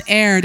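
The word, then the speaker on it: heir: it's pronounced air. There's no H.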